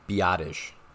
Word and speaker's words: Biadasz: I hear it pronounced so many different ways, but last name spelled B-I-A-D-A-S-Z. And I believe the correct way is Biadasz. Biadasz.